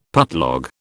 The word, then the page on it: putlog: British English